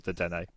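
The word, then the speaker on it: Dedenne: Didene, is that how you pronounce it? – I could be wrong, but that's how I've always pronounced it, is Didene.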